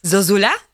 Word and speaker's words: Zozulia: Uh, your surname, I thought that it's pronounced Zozulia, but I just heard you say uh, Zozula. Yes. Yes. Zozula.